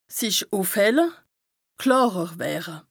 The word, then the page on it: sich ùfhelle, klàrer wëre: Bas-Rhin; Strasbourg